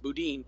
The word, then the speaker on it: boudin: So, and it's actually pronounced boudin, but it's really good.